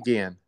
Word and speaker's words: Jungian: Jungian.